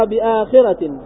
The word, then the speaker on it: بآخرة: ب تغير بآخرة بمد الهمزة وكسر الخاء وفتح الراء بعدها تاء مربوطة.